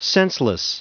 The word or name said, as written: senseless